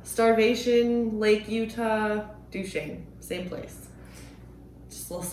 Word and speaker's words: Duchesne: Right out there in eastern Utah of Duchesne.